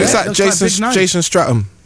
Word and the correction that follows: Statham: Jason Stratham. – Jason Statham, yeah. – Statham. I don't know how you say it.